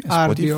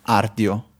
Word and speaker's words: Rdio: Eh, mi piace, è curata e è semplicissima da utilizzare supporta anche diversi servizi, tra cui iTunes, RDIO, penso si pronunci così, Ardio, Spotify, si dica. – Ardio, ok, Ardio, ehm, Spotify, Radium e YouTube.